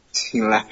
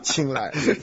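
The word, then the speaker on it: 青睐: 这个字怎么念？亲来是亲妹是亲眼儿？ – 亲来了，亲来。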